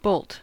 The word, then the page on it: bolt: American English